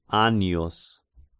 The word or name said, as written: Annius